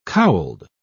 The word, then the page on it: cowled: British English